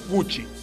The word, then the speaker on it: Gucci: Me dice mm, que Gucci se pronuncia Gucci. Gucci.